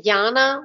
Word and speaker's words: Jana: It's Jana with a J, J-A-N-A, and Herde is my last name, which is very German and very difficult.